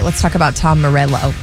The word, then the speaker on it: Morello: When it has two L's, it looks like I should roll my L's. – Oh, yeah, you called him Tom Morello. Before. Morello.